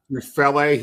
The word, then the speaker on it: Tufele: I mean, obviously, Jay, and I'm not going to pronounce the name right, Tufele, I guess is how you pronounce it. – Tufele.